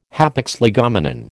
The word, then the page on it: hapax legomenon: American English